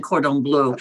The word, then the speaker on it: cordon bleu: Well, you may not speak French, but you pronounced that exactly correctly, chicken cordon bleu.